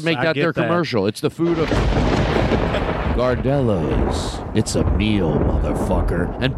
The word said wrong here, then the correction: Gardettos: What are they? Gardettos? Am I saying it right? Yeah. Gardettos.